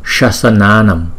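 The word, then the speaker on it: śāsanānāṃ: Thành thử ra chúng ta đọc là Shasananam, Shasananam. Chữ S có dấu sắc, chúng ta đọc là S. Giống như là sugar. Trong tiếng Anh sugar là đường đó, chúng ta đọc là Shasananam, Shasananam.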